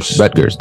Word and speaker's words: Rutgers: Rutgers, that's it.